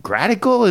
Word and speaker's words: Gratical: Zacuto has announced the Gradical. Am I saying that right, Mitch? – Yeah.